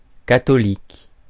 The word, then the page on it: Catholic: [ˈkæθ.lɪk]; /ˈkæθ.ə.lɪk/